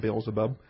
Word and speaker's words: Beelzebub: All right, Beelzebub, or Beelzebub. The B-U-B is a short syllable in English, so we can call him Beelzebub.